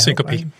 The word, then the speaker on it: syncope: after experiencing what my cardiologist described as vasovagal syncope." Is that how you say it? – Syncope. – Syncope. Cool. Yeah.